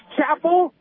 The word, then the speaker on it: Chappelle: His name is spelled like Chappelle, C-H-A-P-P-E-L-L-E, but he says it is Chapel.